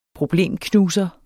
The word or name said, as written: problemknuser